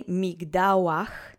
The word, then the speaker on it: migdałach: So, let's say migdałach together slowly three times. The accent is on the middle syllable. Migdałach. Migdałach. Migdałach.